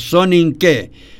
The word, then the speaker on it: soninke: Así que esto forma parte del soninke, así se pronuncia, Carlitos, soninke.